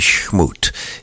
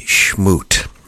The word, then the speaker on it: Chmut: If you've not seen his video links and didn't get our e-blast announcing that, just go to YouTube and ask for Andrei Shmoot. His last name is spelled C-H-M-U-T. Marvelous videos, not only his, but him with Marcus Miller, with Bob James, with Rick Braun. Andrei Shmoot.